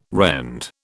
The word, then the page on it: rend: British English